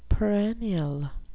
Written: perennial

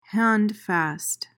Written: handfast

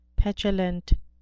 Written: petulant

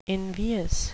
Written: invious

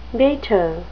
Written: beta

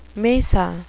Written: mesa

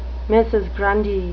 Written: mrs. grundy

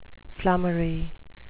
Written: flummery

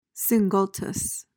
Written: singultus

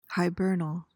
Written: hibernal

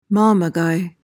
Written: mamaguy

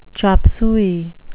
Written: chop suey

